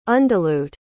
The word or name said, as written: undilute